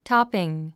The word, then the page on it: topping: tάpiŋ; トピン